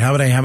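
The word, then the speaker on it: Hamadeh: Chris Mays, the D, leads Abe Hamaday, did I pronounce his name correctly? – Abe Hamaday, that's right, Hamaday.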